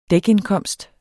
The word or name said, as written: dækindkomst